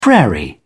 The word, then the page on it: prairie: British English